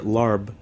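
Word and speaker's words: lob (ลาบ): It's one of those dishes that it's helpful to point to lob when you describe regionality in Thailand, mm-hmm. – because lob, or larb as it's spelled on English menus in the U.S.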